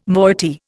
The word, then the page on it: moiety: Indian